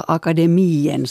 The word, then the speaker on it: Akademien: Ja, alltså om någonsin så vet folk att det finns någonting sånt som Svenska Akademien som man uttalar så det med i-en i slutet.